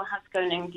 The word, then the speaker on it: Haskoning: And did I say that correctly? Haskoning DHV. – Correct, Royal Haskoning DHV.